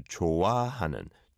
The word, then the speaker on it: 좋아하는: 좋아. Is a 히읗 받침 and then an a sound as a second syllable. So 좋아하는, 좋아하는.